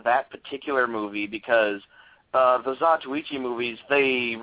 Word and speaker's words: Zatoichi: and it was in a Zatoichi movie. Am I pronouncing that right? – I think it's a Zatoichi. – Oh, Zatoichi movie.